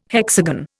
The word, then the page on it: hexagon: Indian